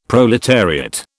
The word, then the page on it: proletariat: British English